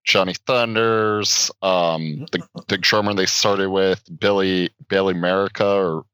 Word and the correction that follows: Murcia: or Merca. – Wait, how do you spell it? – M-U-R-C-I-A. – Oh, and Mercia, yeah.